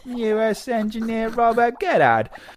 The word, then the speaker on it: Goddard: engineer Robert Goddard, I don't know how to say his last name. – Goddard. G-O-D-D-A-R-D.